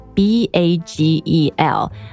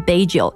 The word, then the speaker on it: bagel: But what's more interesting，除了我刚刚讲那个 English muffin，还有一个圆圆的面包就是 bagel， B A G E L，千万不要读成 bagel， it's bagel。